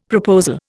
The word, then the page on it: proposal: Indian